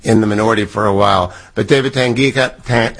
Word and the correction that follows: Tangipa: how do I say it? – Tangipa. – Tangipa.